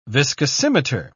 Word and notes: viscosimeter: British English

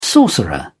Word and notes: sorcerer: British English

